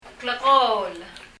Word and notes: klekóol: [klɛkɔ:l]

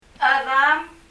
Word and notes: chedam: [? ə(æ)ðəm]

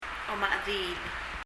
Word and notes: omachediil: ɔｍə ? ɛði:l

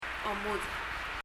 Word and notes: omudech: [ɔmuðɛ ? ]

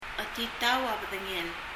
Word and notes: chetitau a bedengel: ? ətitáu ə b(ə）ðəŋɛl

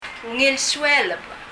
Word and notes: Ungil sueleb: ŋil suɛlɛb

